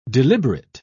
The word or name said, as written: deliberate